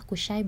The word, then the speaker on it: Qushai: Kushai bin Kilab itu ejaannya Q U S H A I bin Kilabnya biasa gitu jadi yang aku bacain yang aneh-aneh aja ya ejaannya Terus uh, perjanjian Al-Mutayyibin.